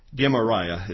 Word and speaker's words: Gemariah: Nevertheless, Elnathan and Delaiah and Garamira, no, that's not right. You guys had to look that up on your own here. Gemariah is the actually pronunciation there.